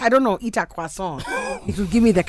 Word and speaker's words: croissant: Come on, how do you say it? – Croissant. – Voilà.